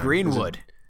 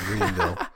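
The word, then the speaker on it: Jonny Greenwood: You know, like, I mean, I know that Johnny Greenville. I swear I butcher that every time. – Greenwood. – Johnny Greenwood. – Yeah, yeah, yeah. – I always think of Johnny Knoxville. That's why I say Greenville. Johnny Greenwood.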